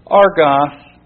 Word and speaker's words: argos: In the Greek, that is argos gartes, garter, sorry. And that is spelled A-R-G-O-S, Argos, and Gaster, G-A-S-T-E-R, Argos, Gaster.